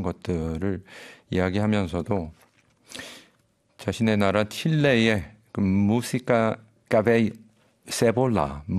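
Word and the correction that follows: cebolla: 무시카 세볼라, 세보야군요. 발음이 세보야 같은 이런 심파 발라드 그리고 엔카.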